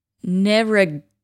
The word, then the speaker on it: Narragansett: Narragansett.